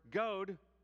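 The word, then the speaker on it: gōd: The word goad, notice the long O, the word goad meant good.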